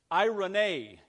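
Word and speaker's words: eirene: And the word is in Greek, Irene. It's spelled E-Irene, pronounced Irene.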